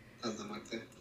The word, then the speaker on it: Santa Muerte: But is it Santa Morita? Morita? Morta? Morta. – Santa Muerte. – Santa Muerte. – Thank you. – Yeah. I don't believe so. She is. Oh, she is? – Santa Muerte.